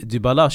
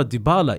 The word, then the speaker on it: Dybala: Nej, de här svenskarna, kommentatorerna säger alltid Dybala, Dybala. Dybala är mycket rättare eh, uttal enligt mig.